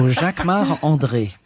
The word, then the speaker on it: Jacquemart-André: You say it like André. – A Frenchman. Jacquemart-André. – Of course, I'm French. So Jacquemart-André.